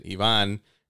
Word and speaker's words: Ivan: Like, because, like, my name's Ivan. In Spanish, you pronounce it Ivan.